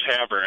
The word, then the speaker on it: Havre: And let's see if we can go now to, Hover in Montana. – Close enough. It's Havre, actually. – Havre. All right.